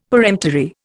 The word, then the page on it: peremptory: Indian